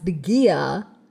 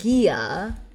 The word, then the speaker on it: Gaia: Piper finally deduces that Gaia, which is pronounced Gaia, I've always pronounced it Gaia or Gaia. – I've always said Gaia. Oh, no. I don't like that. I looked it up online, and it's Gaia.